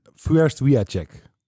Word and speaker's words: Fürst Wiatchek: Fürst Wiatchek. – Fürst Wiatchek. – Eigenlijk fonetisch, gewoon oplezen wat er staat. De meeste mensen kunnen dit uitspreken.